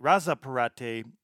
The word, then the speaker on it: Razzapparte: Uh, they can be found at Razparate, Razzapparte on both Facebook and Instagram, and that is spelled R A Z Z A P P A R T E.